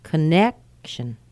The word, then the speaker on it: connection: Connection, connection. Notice how fast that C-O-N is. It's not con. It's not cone. It's con. Connection.